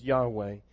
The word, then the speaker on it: Yahweh: Jehovah is a German mispronunciation of a Hebrew combination of two words that's then been mispronounced again into English. The proper pronunciation is Yahweh.